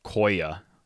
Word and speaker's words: Koya: Apparently it's pronounced Koya, because it's short for the real name, Sequoia.